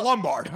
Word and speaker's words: Lombard: I'll try and church it up, son. It's Lombard.